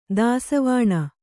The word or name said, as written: ದಾಸಿವಾಣ